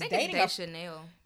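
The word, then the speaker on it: Deschanel: Who is Dechanel.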